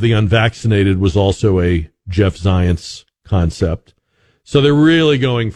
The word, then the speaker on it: Zients: I think it's Jeff Zients is how you pronounce his name, with a Z, but it sounds like science.